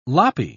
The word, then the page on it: loppy: British English